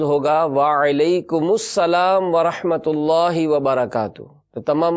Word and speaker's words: وعلیکم السلام: سلام کا جو جواب ہے اس کا درست تلفظ ہے وعلیکم السلام۔